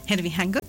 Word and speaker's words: Herbie Hancock: De hecho, el embajador de buena voluntad de la UNESCO para el jazz es el legendario pianista y compositor Herbie Hancock. No sé si lo pronuncié bien. – Ajá, Herbie Hancock.